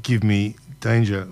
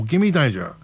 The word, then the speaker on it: Gimme Danger: That was the Stooges, Iggy and the Stooges, or should I say Iggy Pop and the Stooges, and Give Me Danger, or Gimme Danger is the way to pronounce that one.